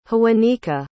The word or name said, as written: hoowanneka